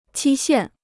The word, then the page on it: 期限: qī xiàn